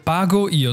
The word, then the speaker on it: Pago Io: Ja, du, Pago Io, so wie Pago das Fruchtgetränk.